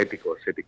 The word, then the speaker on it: Ethikos: Sí, sí, sí, viene del griego, pero bueno, lo pronunciamos en español, en castellano, vale. Ethikos.